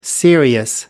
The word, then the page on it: serious: American English